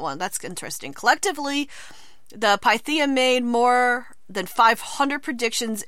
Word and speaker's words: Pythia: Pythia, yes.